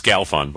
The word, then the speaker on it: Galfond: I love that you don't know how to pronounce his name still after all these years. It's Galfond, by the way.